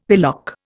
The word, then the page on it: pillock: Indian